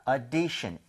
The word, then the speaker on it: audition: 오디션이 아니라 5에서 조금만 더 벌려주시면 돼요. 그래서 audition, 아하. – audition 조금만 벌려서 5보다는 차라리 어에 더 가까운 게 낫습니다.